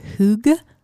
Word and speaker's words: hygge: It's like, I think it's pronounced like hygge or something, but it's spelled H-Y-G-G-E.